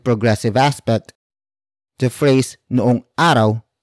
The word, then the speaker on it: araw: And that is araw.